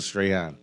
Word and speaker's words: Strahan: That was just how he pronounced his name, believe it or not. Strahan.